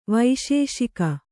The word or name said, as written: ವಿಶೇಷಕ